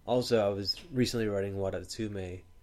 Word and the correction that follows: Wadatsumi: It was Wadatsume? – Wadatsumi. – Wadatsumi. – I said it wrong and just lured you into it.